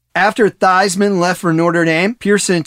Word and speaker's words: Theismann: You may have noticed that Drew mentioned Joe Theismann went by Theismann at the time. And that was true before Theismann went to Notre Dame, where Theismann rhymes with Heisman, became a catchphrase.